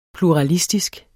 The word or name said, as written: PLURALISTISK